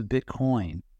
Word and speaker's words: Bitcoin: But in the case of Bitcoin, right? I put the emphasis on the wrong syllable. But in the case of Bitcoin, it's about demonstrating the ability to cooperate.